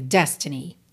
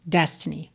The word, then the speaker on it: destiny: Aqui na música, essa palavra destiny acabou ganhando uma ênfase aí na última sílaba, né? Normalmente, a sílaba tônica é a primeira, destiny, destiny, ok? Mas aqui na música, destiny.